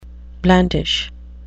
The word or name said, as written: blandish